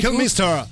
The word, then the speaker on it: Kilmister: L'oggetto della 35esima puntata è Lemmy Kilmister, come lo pronunci Kilmister.